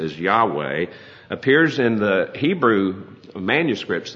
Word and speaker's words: Yahweh: And the consonants are what we would transliterate as YHWH, and the W can also be pronounced like a V, so YHVH, Yahweh or Yahweh.